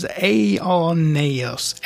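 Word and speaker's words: aionios: aioneios. It's A-I-O-N-I-O-S in the Greek, okay? – Mm-hmm. – And the emphasis is on the exact same syllable on everything, and it means age-lasting, okay? – Right, age-lasting, yeah.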